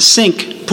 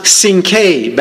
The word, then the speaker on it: Cinque: And he was the sailing master of the ship, and again, it might sound funny, the ship's name was C-I-N-Q-U-E, Ports. The word is pronounced Sink, Ports. Now, why any sailor would get on a ship named Sink is anyone's guess. But some say, oh no, it was pronounced Sinke back then.